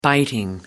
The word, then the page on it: biting: American English